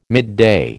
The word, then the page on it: midday: American English